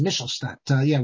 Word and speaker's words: Michelstadt: And the correct pronunciation is Michelstadt, with German. But in Yiddish, we say Michelstadt, because it's with a chaf.